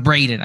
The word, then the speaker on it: Breiden: Is it Brendan or Braden? – Braden. I think I'm saying that incorrectly.